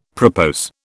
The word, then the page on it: propose: British English